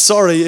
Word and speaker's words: Surrey: So embarrassing later on when I found out that Surrey is pronounced sorry if you've got the right English accent, and embarrassment forever.